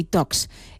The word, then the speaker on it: Talks: Son charlas de tecnología, por eso Tech y Talks. Es que no se pronuncia la L, queremos ir de casi nativos, ¿no? Hay que pronunciarlo bien.